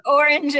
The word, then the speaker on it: Orange: So in this case, I know you say Orange. It's very fancy, but Orange and Nokia. – The French pronunciation, right?